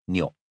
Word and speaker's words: Nyok: So Mei Ling had compromised and invented the name Nyok with a short O, and so he'd remained ever since.